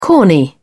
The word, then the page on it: corny: British English